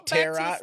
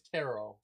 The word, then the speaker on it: Tarot: He said it so like Tarot Reader. – No, it's Tarot. – Tarot. Oh, Tarot. Okay. – Yeah, the T is silent.